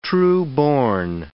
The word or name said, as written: trueborn